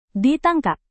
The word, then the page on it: ditangkap: Indonesian